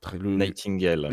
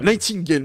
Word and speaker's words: Nightingale: Il s'agit de Nike. Euh, euh, ah, Nightingale. Nightingale, merci. J'arrivais pas du tout à le prononcer.